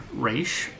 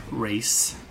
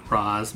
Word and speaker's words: Ra's: Ra's al Ghul, pronounced either Raish or Race, not Ra's, Mr. Nolan, you expletive deleted amateur, isn't my favorite villain.